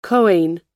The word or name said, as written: kohen